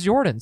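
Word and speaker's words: Yordan: It's pronounced Jordan, but I always hear it as Jordan, so I'm going Jordan.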